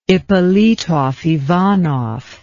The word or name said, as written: Ippolitov-Ivanov